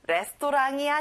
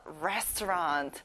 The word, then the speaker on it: restaurant: 여러분, 레스토랑이 아니라 다시 한번 restaurant.